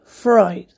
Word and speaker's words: Freud: Sí, se escribe Freud, pero se dice Freud.